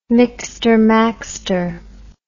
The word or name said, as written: mixter maxter